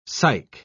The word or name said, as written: psych